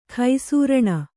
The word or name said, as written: ಘುಸೃಣ